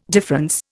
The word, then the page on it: difference: Indian